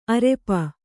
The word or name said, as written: ಅರಿಪೂ